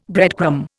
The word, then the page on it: breadcrumb: Indian